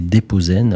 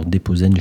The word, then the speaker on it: Depozen: Aujourd'hui, c'était Déposen. Alors Déposen, je ne l'ai pas dit au début, je m'en excuse, mais ça s'écrit D-E-P-O-Z-E-N.